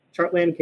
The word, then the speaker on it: Chartland: So if you just Google Chartland, it rhymes with Heartland, Chartland KC Mo should come up for you.